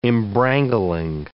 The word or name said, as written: embrangling